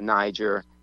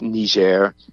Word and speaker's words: Niger: And we call it Niger. The French pronunciation is Niger, so whatever.